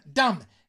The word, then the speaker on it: damn: You replace God with gosh, okay, so oh my god, I think you say damn, D-A-M-N, okay, y luego it, pero la última N, okay, is silent, okay, it's last N is silent,